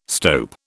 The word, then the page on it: stope: British English